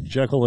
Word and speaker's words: Jekyll: Dr. Jekyll and Mr. Hyde. – You know, it's actually pronounced Jekyll.